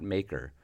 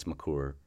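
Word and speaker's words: Makar: I'm sure I butchered the last name, but it's not Maker. I think it's Makur.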